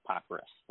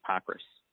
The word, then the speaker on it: Pockrass: I would say that you have it right, Pockrass, like R-U-S-S.